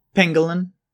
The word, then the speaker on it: pangolin: I said that right. – Pangolin. – I did say it wrong.